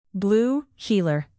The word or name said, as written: blue heeler